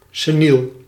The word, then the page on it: seniel: /seˈnil/